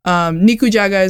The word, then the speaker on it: nikujaga: I've probably done this joke before, but nikujaga is how you say it in Japanese, and to say that nikujaga is Mick Jagger's favorite food because it sounds like mikujaga.